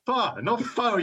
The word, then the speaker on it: pho: Pho, you moron. Pho. – What is it? – Pho. – Pho. I'm saying how it's written, pho. – Yeah, but it's not written for English people to pronounce, is it? In Vietnamese, it's pronounced pho. – Okay, pho.